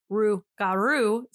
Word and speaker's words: rougarou: R-O-U-X dash. Well, yeah. G-A. Hyphen R-O-U-X. – But when you pronounce it in French, it's rougarou. So, you know, English people being the lazy little mother heckers they are, they change it to literally how it sounds, rougarou, R-O-U-G-A-R-O-U. The most easy sounding way they could do it.